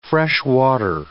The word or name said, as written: freshwater